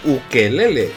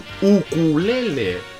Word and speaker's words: ukulele: Y no es un ukelele, es un ukulele.